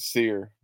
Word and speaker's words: Cyr: But pronounced as Sear, C-Y-R, Sear Malonga.